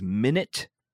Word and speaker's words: Minit: Number four is Minute, which is spelled MINIT, which is a game.